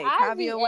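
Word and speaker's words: Kaavia: I-V-A. No, it's I-V-A. – K-A-A. No, it's K-A-A-V-I-A. Kavya Wade. Kavya James Wade.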